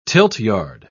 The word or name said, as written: tiltyard